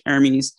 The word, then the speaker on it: Hermes: I don't think you pronounce the H, Hermes.